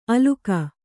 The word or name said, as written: ಆಲುಕ